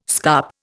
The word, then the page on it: scup: Indian